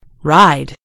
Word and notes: ride: British English

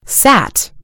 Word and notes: sat: American English

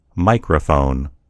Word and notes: microphone: American English

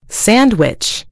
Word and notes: sandwich: American English

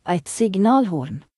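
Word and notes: air horn: Norwegian Nynorsk

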